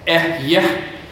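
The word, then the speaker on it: Eh-Yeh (אהיה): It is, in the Hebrew, it's pronounced Eh-Yeh, but we pronounce it Yahweh, right?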